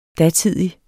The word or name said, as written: datidig